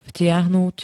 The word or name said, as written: vtiahnuť